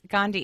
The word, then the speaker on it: Gandhi: Yes, Gandhi.